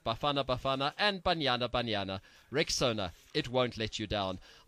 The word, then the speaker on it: Rexona: So let me just remind you, guys, I'm sure you've seen that Shield is now Rexona, but it appears some people don't know how to pronounce this new name. A friend even called it Rexona. But guys, it's Rexona, like Rexona. Rexona.